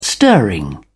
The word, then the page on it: stirring: British English